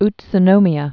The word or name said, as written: Utsunomiya